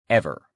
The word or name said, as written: ever